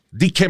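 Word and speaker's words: Dikke: Dike Manuel, el Manuel el gordo.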